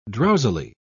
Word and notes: drowsily: British English

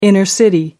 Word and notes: inner city: American English

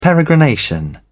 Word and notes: peregrination: British English

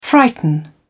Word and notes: frighten: British English

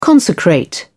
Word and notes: consecrate: British English

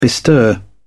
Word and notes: bestir: British English

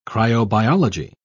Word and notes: cryobiology: British English

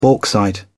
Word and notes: bauxite: British English

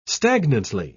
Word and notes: stagnantly: British English

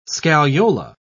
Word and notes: scagliola: British English

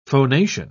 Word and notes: phonation: British English

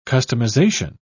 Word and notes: customization: British English

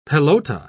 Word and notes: pelota: British English

